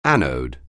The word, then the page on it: anode: anglais